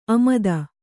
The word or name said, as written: ಆಮೋದ